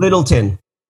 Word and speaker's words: Littleton: So this is David, is it David like Lighton? How do you say his name? – Littleton. – Littleton, okay.